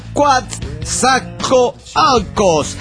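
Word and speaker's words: Coatzacoalcos: La primera es que se pronuncia Quatzacoalcos. Esto viene porque el programa anterior alguien les mandó saludos desde ahí y no lo supieron pronunciar.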